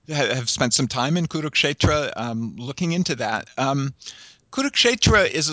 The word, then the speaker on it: Kurukshetra: So you're dealing with a text that's connected to an identifiable geographical location, and correct me if I pronounce this wrong, but Kurukshetra? – Kurukshetra, yes.